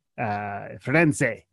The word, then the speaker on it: Firenze: Florence, Firenze. Firenze is how they say it in Italian.